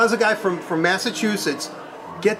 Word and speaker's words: Massachusetts: I want to get into your job, but first, I know you're from the East Coast, Massachusetts. I did say that correctly, right? – Yes.